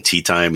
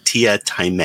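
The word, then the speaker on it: Teatime: There was a movie version of Hogwatch that was done for Terry Pratchett, and there's a character in there called he calls himself Tia Time, but it's spelled Tea Time, so people keep calling him Tea Time, and he's like, no, it's Tia Time, and it's just the funniest thing the way he talks.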